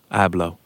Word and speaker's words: Abloh: Abloh.